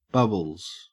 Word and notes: bubbles: Australian English

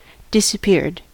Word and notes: disappeared: American English

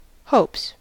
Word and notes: hopes: American English

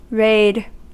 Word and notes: raid: American English

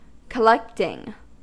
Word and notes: collecting: American English

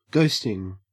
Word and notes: ghosting: Australian English

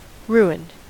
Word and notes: ruined: American English

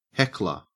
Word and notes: heckler: Australian English

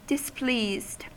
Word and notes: displeased: American English

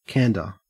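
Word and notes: candor: au Australian English

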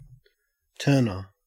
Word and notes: turner: Australian English

